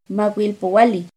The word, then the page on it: macuilpohualli: Náhuatl